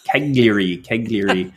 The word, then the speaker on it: Cagliari: I joke that it sounds to my Canadian ears like Calgary, but of course it's not. It's Cagliari.